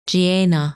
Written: Gienah

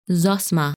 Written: Zosma

